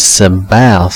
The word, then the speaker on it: Sabaoth: Now this is not Sabbath, it is Sabaoth. Sabaoth? I don't know how to pronounce that. It's S-A-B-A-O-T-H.